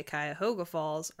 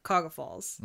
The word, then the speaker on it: Cuyahoga Falls: Because you can tell if you're from there based on whether or not you say Cuyahoga Falls or Cuyahoga Falls. – Mm-hmm. – People that are from Cuyahoga Falls will drop 3 syllables out of the middle of the word.